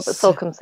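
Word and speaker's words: Salcombe: Marco, I think we mentioned your glorious Newcastle at the moment, but apparently he is heading to, now how do you pronounce that? Is it Solcombe? Yeah, Solcombe at the weekend for a wedding, and the weather might not be so good.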